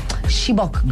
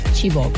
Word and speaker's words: Chibok: and you've always been in support of the Chibok girls. How do I pronounce that? – Yeah, well, I pronounce it Chibok.